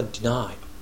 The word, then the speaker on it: deny: 綴りは deny でデニーみたいに書くんですが、発音は deny